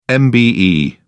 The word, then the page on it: MBE: British English